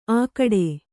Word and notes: ಆಗಡೆ: Kannada

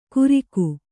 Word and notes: ಕುರಿಕು: Kannada

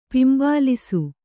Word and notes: ಪಿಂಬಾಲಿಸು: pimbālisu

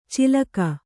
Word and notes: ಚಿಲಕ: cilaka

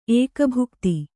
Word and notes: ಏಕಭುಕ್ತ: kannada

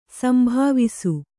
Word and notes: ಸಂಭಾವಿಸು: sambhāvisu; Kannada

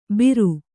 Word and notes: ಭೀರು: Kannada

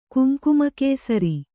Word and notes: ಕುಂಕುಮಕೇಸರಿ: kuŋkuma kēsari

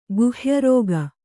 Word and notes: ಗುಹ್ಯರೋಗ: Kannada

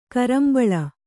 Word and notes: ಕುರುಂಬಳೆ: kurumbaḷe

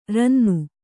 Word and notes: ರನ್ನ: ranna

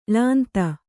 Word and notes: ಳಾಂತ: ḷānta

